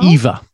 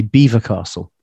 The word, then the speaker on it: Belvoir: Beaver. It's spelt Belvoir, but yeah. It's pronounced Beaver. They say Beaver Castle.